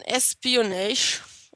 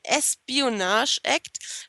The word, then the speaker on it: Espionage Act: Und dann kommen wir zu dem sogenannten Espionage. Ich habe das schon wieder falsch gesagt. Act. – Espionage-Act.